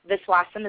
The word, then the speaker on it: Viswasam: My name is spelled S-H-A-R-M-I-L-A. Viswasam is my last name, V as in Victor, I-S-W-A-S-A-M.